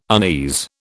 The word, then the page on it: unease: British English